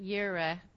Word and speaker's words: Jireh: Jehovah, you're supposed to pronounce it Yireh, J-I-R-E-H.